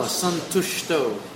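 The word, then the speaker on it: santuṣṭo: Yadrichcha, labha, santushto. Again, the dot under the S makes a S into a sh. So that diacritic mark, that dot, changes the pronunciation.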